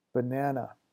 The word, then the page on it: banana: English